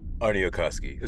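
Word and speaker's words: Aarniokoski: This episode was written by Christopher Moffat and Sean Trotta, and the director, let me take this moment to correct my pronunciation last week of Doug Arniokoski's name. I may have mispronounced that last week, I apologize. Arniokoski, as far as I know.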